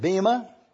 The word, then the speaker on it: bema: If you look carefully at this word, you will discover that it is B-E-M-A-T-O-S. Bematos. We pronounce this word Bema.